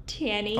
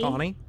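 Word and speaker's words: Taney: John Rutledge, Oliver Ellsworth, John Marshall, Roger Tanny. – Tawny.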